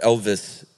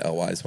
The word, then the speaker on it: Elwes: He continued on his normal ways, and there were three gentlemen named Chris Sarandon, Carrie Elwes, or Elvis, apparently, is how you really pronounce it. – Really? – Yeah, I've been saying Elwes my whole life.